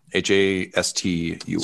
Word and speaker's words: Hastur: And I'm sorry, I misspoke in the heat of battle. It's Hastur, Hastur. – Sorry, I might have actually... – No, dude, you. I 100% said it wrong because,